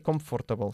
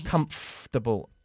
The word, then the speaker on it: comfortable: Muy bien, pero no se dice comfortable, sino comfortable. Casi omitimos el sonido de OR en medio.